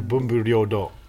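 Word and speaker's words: bunbu ryōdō: Bunbo do. – No, bunbu ryo do.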